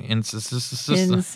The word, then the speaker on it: Insys: It starts with an IN. Insys.